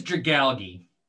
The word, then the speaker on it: Dragalge: I think it's Dragalge. – Dragalge. – Or Dragalge. Because it's like algae, but also Dragalge. – Dragon algae.